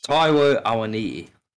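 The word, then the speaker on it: Taiwo Awoniyi: Taiwo Awani.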